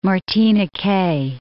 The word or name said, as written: Martiniquais